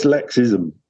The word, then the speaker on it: Lexism: So, your theory of um, lexium, did I say that correctly then? – That was lexism.